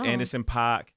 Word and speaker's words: Anderson .Paak: Anderson .Paak, and I believe it's his.